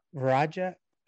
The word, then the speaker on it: Freya Vajra: Varja. – I knew I was going to butcher that name.